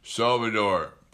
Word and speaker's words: Salvador: and he can become the he becomes Salvatore Perez. Salvador Perez.